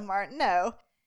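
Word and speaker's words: Martenot: It was created by Maurice Martineau, I'm assuming, because you don't pronounce the T, right, in French. – Yeah. – So Martineau.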